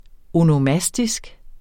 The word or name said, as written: onomastisk